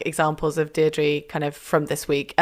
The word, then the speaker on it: Deirdre: I like Dear Deirdre better. Did I say it right? – You did. – Nailed it.